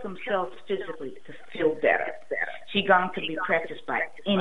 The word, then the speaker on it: qigong: Just type in qigong, and it's spelled Q-I. Okay, it's pronounced qi, like the qi in your body. It's Q-I, and G is in good, O-N-G. So qigong for beginners is my favorite.